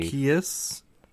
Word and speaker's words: Arceus: Arceus? Because he's not an arse, he's an arc.